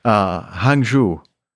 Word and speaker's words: Hangzhou: Hangzhou.